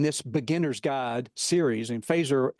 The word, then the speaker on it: Feser: When you see it, it looks like Fesser, F-E-S-E-R, but it's pronounced Fazer like the weapon in Star Trek.